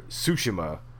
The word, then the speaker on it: Tsushima: The character you play as, Jin Sakai, is an honorable samurai on the island of Tsushima. I'm sorry, Tsushima.